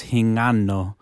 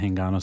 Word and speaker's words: Hingano: It's really pronounced Hingano. – Oh. – Okay, Hingano. – But people pronounce it Hingano, so I just go with it.